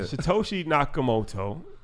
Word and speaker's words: Satoshi Nakamoto: Satoshi Nakamoto.